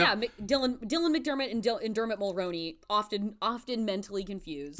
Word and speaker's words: Dermot Mulroney: Dermot Mulroney. I knew I was going to say it wrong. I keep saying his name wrong, like Dermot Mooney.